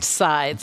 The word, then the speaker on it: Zai: Sai. Yep. – Spelled with a Z. – I know. It's pronounced Sai.